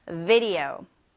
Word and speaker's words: video: video. video. video. 네, V로 시작하니까 우 이렇게 이를 아랫입술에 살짝 갖다 대면서 우 발음하는 거 잊지 마세요.